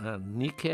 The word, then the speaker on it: Nike: A mesma grafia, só que a pronúncia não é a moda inglesa. Nike é a deusa da vitória.